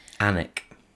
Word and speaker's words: Alnwick: Is it Alnwick? – Alnwick. – Alnwick, is that how you say it? – I think so, but then... – Yes.